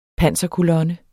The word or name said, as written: panserkolonne